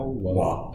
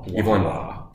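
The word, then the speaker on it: Waugh: So we are going to start from the top down, Brideshead Revisited, written by Evelyn, how do you say Wow? – Wow. Evelyn Wow. Yeah, everyone debates that.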